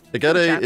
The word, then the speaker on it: Egede: E-G-E-D-E. – Oh. – It's a place in Galarian. – Egede. – Oh, here we go. Here's the pronunciation. Okay. Egede. – Egede. – Egede. – So I was hard. I was right with the hard G. – Yeah, you're right. It's hard.